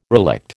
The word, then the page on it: relict: American English